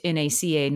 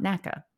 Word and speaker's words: NACA: It is where I learned about the NACA, which is a real thing, which is the NASA precursor, and interestingly pronounced NACA, not NACA.